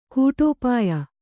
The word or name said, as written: ಕೂಟೋಪಾಯ